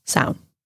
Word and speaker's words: Samhain: So it's spelled like Samhain, Samhain, mm-hmm. but it's pronounced Samhain. – Samhain. – Okay. – Or one syllable, just sound.